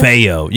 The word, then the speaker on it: DeFeo: How do you say their last name? I always say it wrong. – DeFeo. – Junior. – DeFeo. You got to say it like a Guido.